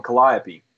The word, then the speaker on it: Calliope: That the weirdos, and I mean that in a loving way, seem to congregate in there and also in front of, she's going to get me because I'm going to say it wrong, Calliope. – No, you said it right. – You said it wrong. – You said it exactly right.